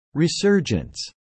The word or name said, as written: resurgence